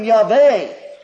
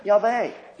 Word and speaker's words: Yahweh: We don't know how it was pronounced. The Hebrews, instead of pronouncing it, substituted a different word, the word Lord. If we attempt to pronounce it, we would read it something like, "I am Yahweh, thy God."